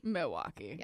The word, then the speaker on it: Milwaukee: Milwaukee. – Yeah. – You don't say the L.